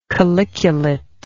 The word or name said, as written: calyculate